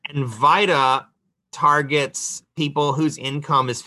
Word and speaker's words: VITA: The acronym for that is pronounced VITA like vitamin, not Vita like Velveeta cheese.